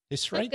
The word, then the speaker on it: Hissrich: Hissrich? – Good to me.